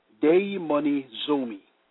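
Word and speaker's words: daimonizomi (δαιμονίζομαι): The Greek word for possessed is daimonizomi, pronounced daimonizomi,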